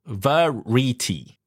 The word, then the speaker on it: variety: Yes, but if you cut the syllable like this, V-A, R-I-E-T-Y, then you probably pronounce it variety, right? Variety. So no, it's variety. So it's V-A, and then R-I, and then E, and then T-Y. Okay. Variety.